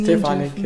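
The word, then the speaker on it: Štefanek: Bei einem Trainingscamp hast du damals mit dem serbischen Olympiasieger davor, Stefan, falls ich den richtig ausgesprochen habe. – Ja, das ist Stefanik, ja. Genau.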